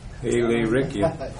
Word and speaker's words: Illyricum: Illyricum.